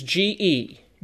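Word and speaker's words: ge (γῆ): There is a Greek word for the physical planet, and it's the Greek word ge, which is G-E, and it's just pronounced ge.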